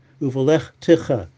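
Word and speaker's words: uvelech techa (ובלכתך): So that's an example where the chaf has a shva nach, uvelech techa, and the taf has a shva nad, uvelech techa is the right way to pronounce it.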